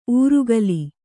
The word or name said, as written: ಊರುಗಲಿ